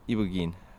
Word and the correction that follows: iboga: No? Iboga. – Iboga, yes.